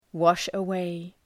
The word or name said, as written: wash-away